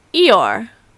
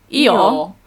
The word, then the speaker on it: Eeyore: And in British English, it would be Eeyore, but it's a similar pronunciation if you say it with a British accent. Eeyore.